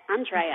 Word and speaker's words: Andrea: Yes, Andrea.